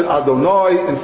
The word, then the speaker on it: Adonai: we pronounce it Adonai instead of the way it's written.